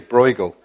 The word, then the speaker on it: Bruegel: Peter Bruegel, I'm not sure that pronunciation is correct. Bruegel, thank you, Bruegel.